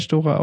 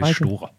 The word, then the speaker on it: Storer: Ja, nee, einmal doch Michael Storer auf Etappe 7, der wurde belohnt, auch nicht nur mit dem Etappensieg, sondern auch für die Kettenattacke. – Eurosportmäßige Aussprache. Michael Storer. – Ja, Storer. Sorry. Michael Storer.